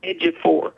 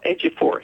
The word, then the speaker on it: Ejiofor: Well, no, when he gets the Oscar, and his name is Chiwetel Ejiofor.